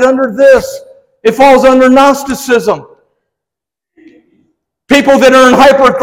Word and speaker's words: Gnosticism: I asked Jamie to put, I want you to see how that's spelled, Gnosticism, silent G.